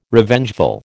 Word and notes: Revengeful: American English

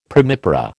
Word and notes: primipara: American English

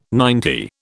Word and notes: ninety: British English

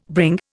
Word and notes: bring: Indian

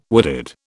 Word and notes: wooded: British English

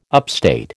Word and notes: upstate: American English

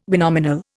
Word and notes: binominal: Indian

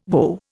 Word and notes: WO: Indian

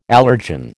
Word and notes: allergen: American English

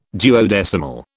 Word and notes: duodecimal: British English

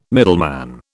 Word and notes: middleman: British English

